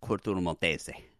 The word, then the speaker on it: Corto Maltese: Corto Maltese.